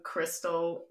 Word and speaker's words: Crystal: Just look up my name, Crystal Lubick, because I rank for literally nothing right now.